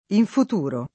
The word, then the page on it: infuturare: lingua italiana